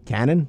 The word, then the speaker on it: Canaan: I believe it's Canon. C-A-N-A-A-N. – Okay. – Canon. – Canon. – Canon?